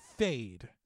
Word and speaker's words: Feyd: His name is Fade in the movie. Like, I know it's spelled F-E-Y-D, but it's pronounced at, like, F-A-D-E.